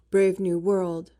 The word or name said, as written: brave new world